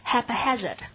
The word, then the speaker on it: haphazard: 根据发音，同学们可以很轻松地拼出这个词型 haphazard， so H-A-P-H-A-Z-A-R-D， the stress is on the second syllable，重音是在第二个音节上。先跟我们把它读说 haphazard， haphazard， yeah，它也表示任意的、无计划的、随意的，但这个形容词的侧重点并不是说不加选择的，而是表示没有计划的，很随意的。